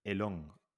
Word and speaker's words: Elon: elong Tomás. Ah, Elon, vale, vale.